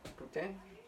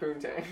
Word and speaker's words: poutine: No, it's actually pronounced poutine. Not poutine.